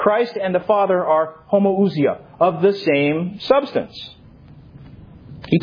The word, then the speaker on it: homoousia: The word is homoousia. I'll spell it for you. Again, I don't have a transparency. Homoousia. And this is Greek. H-O-M-O-O-U-S-I-A. H-O-M-O-O-U-S-I-A. Homoousia. It's from two Greek words.